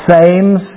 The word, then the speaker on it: Thames: By the way, it's spelt T-H-A-M-E-S. Looks like Thames, but it's pronounced Thames.